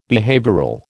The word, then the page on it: behavioural: American English